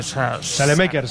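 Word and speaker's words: Saelemaekers: ¿Cómo se pronuncia? – Salemaker, sí. Salemaker.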